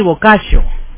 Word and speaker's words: Boccaccio: Giovanni Boccaccio. Boccaccio se escribe B-O-C-C-A-C-C-I-O. Lleva 4 C, lleva su nombre. Boccaccio.